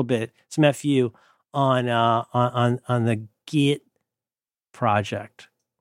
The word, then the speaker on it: Git: Have you heard the good news about Git? – Git. You sound like you're talking like me when I say get, and you say Git. – That's how you pronounce it, right? – Yeah.